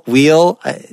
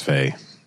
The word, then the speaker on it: Weil: But I think for Americans, wheel. – No, let's say Weil, just because I looked at a bunch of stuff online, and every lecture or podcast you can find, they pronounce it as Weil.